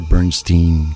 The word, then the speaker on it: Bernstein: Question de hanter le tout Lanaudière, avec des musiques angoissantes comme celle qu'on entend en ce moment, une musique de Charles Bernstein, ou j'imagine qu'en anglais on prononcerait Bernstein, comme Leonard Bernstein, bref, Charles Bernstein.